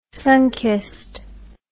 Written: Sunkist

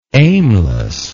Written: aimlessness

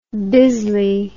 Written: bisley

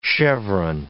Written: cheveron

